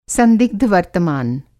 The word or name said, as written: संदिग्ध वर्तमान